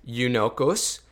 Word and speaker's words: eunuchos: This Greek word, eunuchos, which is um, how you pronounce it in Greek,